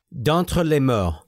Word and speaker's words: D'entre les morts: titled De Alta les Morts de Morts. I don't know. My French oh, wait, pronunciation I can speak French. Hold on. – sucks. D'entre les morts, D'entre les morts. There we go.